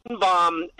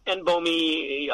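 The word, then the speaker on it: NBOMe: N-bomb, N-bomy, however you want to call it, is a very different chemical.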